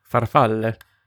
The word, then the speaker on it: farfalle: Farfalle. – Correct. And well pronounced.